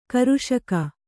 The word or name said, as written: ಕೃಷಿಗೈ